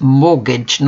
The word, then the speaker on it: mortgage: Mortgage is a noun, a loan from the bank to buy a house. Note, do not pronounce the T, it's mortgage, not mortgage.